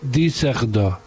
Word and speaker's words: Düsseldorf: Es que Düsseldorf lleva la Düsseldorf, que se escribe, lleva dos puntos en la U. Y entonces se dice Düsseldorf.